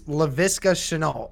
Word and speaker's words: Laviska Shenault: It was Laviska Shenault. Yeah, that's all right. That's how you said it. Yeah, it's how it is. You got it pretty close.